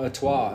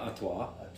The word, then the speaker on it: Artois: How do you say the second part? – Artois? Huh? That's a bougie in a... I think that's how you say it. Stella Artois.